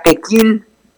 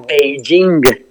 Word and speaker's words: Beijing: Por ejemplo, yo era chica, aprendí que la capital de China era Pekín. Ajá, así, sí, Pekín, sí. P-E-K-I-N. Uh-huh. Cuando la capital es China se pronuncia Beijing.